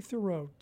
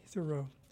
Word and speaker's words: Thiroux: Okay, Katie Thoreau, T-H-I-R-O-U-X, Katie Thoreau.